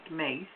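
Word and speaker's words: Myss: I found out about Carolyn Mace, who is the author of the book Sacred Contracts. – Mm-hmm. – It's M-Y-S-S, and it's pronounced Mace.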